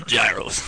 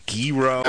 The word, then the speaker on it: gyro: Give me one of those gyros. – And then people swear it's a gyro. – I'm pretty sure it's a gyro. – Gyro? – Yeah. No. Sort of. Like a hero.